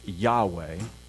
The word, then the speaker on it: Yahweh: And so Yod, He, Vav, He, those four letters, we believe, are pronounced Yahweh, the covenant name of God. And so that's why you will hear me pronounce it that way.